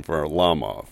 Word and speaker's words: Varlamov: Notice the pronunciation, Varlamov, right? – Well, you're always a professional.